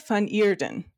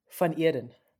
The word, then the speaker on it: van Eeden: And then our second guest today is Mandela van Eeden. Did I say that right, Mandela? Again, van Eeden. Van Eeden.